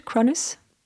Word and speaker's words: Cronus: Cronus? – Yeah, Cronus.